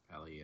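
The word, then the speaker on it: Peli: Was it Pelly or Peely? Uh, Pe- uh, Pelly, I think. – yeah, Pelly. Yeah.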